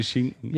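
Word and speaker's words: Shinken: Und zwar nicht so, wie es ausgesprochen wird in Deutsch, sondern mehr so wie dieses japanische Schinken. – Japanischer Schinken. – Also und, wie Schinken nur ohne C.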